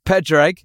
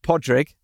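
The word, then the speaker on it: Pádraig: Pedreg, who says it's pronounced Podrig. So sorry, Podrig.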